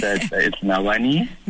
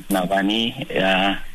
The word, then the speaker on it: Navhani: It's Navhani. – Navhani, yes. – Yes, my name is Navhani.